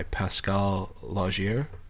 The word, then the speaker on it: Pascal Laugier: But yes, it was a French film directed by Pascal Laugier. Is that how you pronounce it? – Sure.